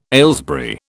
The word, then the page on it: Aylesbury: British English